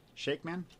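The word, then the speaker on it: Shakman: New Star Trek movie with Chris Pine and his crew, but the director for it is the one that directed WandaVision, Matt Shakeman.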